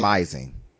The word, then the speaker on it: smizing: They call that like, smizing.